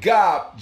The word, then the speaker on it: Gab: Next up, we got the homeboy Gob, G-O-B, but it's spelled G-A-B.